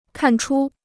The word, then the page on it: 看出: kàn chū